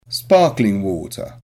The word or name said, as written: sparkling water